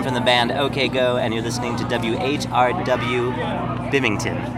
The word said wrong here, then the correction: Binghamton: Is that right? – Binghamton. – Bimington. How do you say it? – Binghamton. – Binghamton. – Yeah. – Oh, I spelled it wrong. How do you spell it? – B-I-N-G. – Oh. – H-A-M. – Oh. Why didn't you tell me? – Sorry, man. – Binghamton. – Yeah, Binghamton.